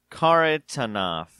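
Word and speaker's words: Kharitonov: So this guy, there's a fighter named Matt Mitrione and a fighter named Sergey Karitonov. – Good job. Well pronounced.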